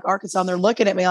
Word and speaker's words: Arkansas: Some people don't know that's how you pronounce it. And you would think that, you know, you learned your state, but no. Or people from foreign countries think it's Arkansas. And that's okay. It's an Indian word. And the S on the end is silent or has a W sound.